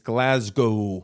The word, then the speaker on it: Glasgow: Please, before you come to Glasgow, get the pronunciation right. It's Glasgow.